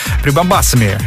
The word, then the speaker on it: прибамбасами: Едем по эфиру прямо с музыкой и со светом, со всеми прибамбахами. Прибамбасами, извиняюсь, да, так же правильно говорить.